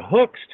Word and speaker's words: Huck: And that's a guy who can knock you out, especially with the hooks, too. I mean, it says H-U-C-K in the last name, but it's pronounced Hook.